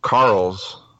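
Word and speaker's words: Carles: In- Carl. Carl's without a without the H.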